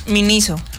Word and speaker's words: Miniso: ¿Se dice así? – ¿Por qué? ¿Cómo es? – Miniso. – Miniso. Ah, bueno.